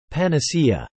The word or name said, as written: panacea